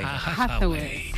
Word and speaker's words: Hathaway: Anne Hathaway. – ¿Cómo? – Anne Hathaway. – Hathaway.